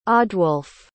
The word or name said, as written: odwulf